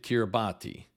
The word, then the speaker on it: Kiribati: By the way, the name of that country is spelled K-I-R-I-B-A-T-I. For the longest time, I pronounced it Kiribati, but it is actually pronounced Kiribati.